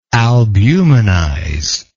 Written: albumenizer

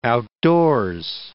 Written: outdoors

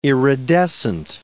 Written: iridescent

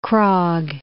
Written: Krogh

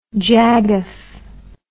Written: jagoff

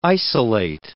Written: isolate

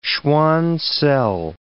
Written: Schwann cell

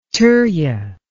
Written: turya

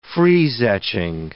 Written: freeze-etching

